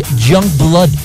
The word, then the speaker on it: Yungblud: His name is Youngblood, but you have to spell it with a U, okay? Y-U-N-G-B-L-U-D.